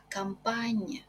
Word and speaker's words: campagne: Буква сочетания ЖН читается как НЬ. Лё пенне, ля монтанне, лё синне, лё манитофонне, ля кампанне. Qui va à la campagne?